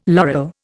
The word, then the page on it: laurel: Indian